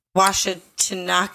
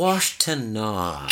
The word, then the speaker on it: Washtenaw: In December of 1966, he was charged at the state court level in Washtenaw County. – It's Washtenaw.